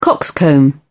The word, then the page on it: coxcomb: British English